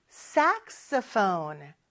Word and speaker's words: saxophone: saxophone. 이렇게 높낮이가 있어요. saxophone.